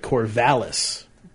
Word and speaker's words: Corvallis: What you pronounce as Corvallis is pronounced like Corvallis. Cor like apple, Corval like a woman's name, and is like is.